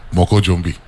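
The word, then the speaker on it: Moko Jumbie: I want to make sure that when you leave St. Croix, right, you actually pronounced it like it's spelt, but it's enunciated different. It's two words, but you actually say it like one word. Moco Jumbie.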